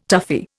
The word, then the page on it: toughie: Indian